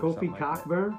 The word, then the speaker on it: Cockburn: Kofi Cockburn. – Like that. Spelled Cockburn. CK is silent.